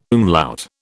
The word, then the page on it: umlaut: British English